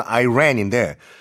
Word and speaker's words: Iran: 이란은 발음이 두 개가 있어요, 여러분. 그 영어 발음이 이란 또는 아이란인데 Iran has been hit hard by 뭐뭐뭐뭐뭐 정말 큰 타격을 받았다.